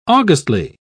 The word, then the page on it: augustly: British English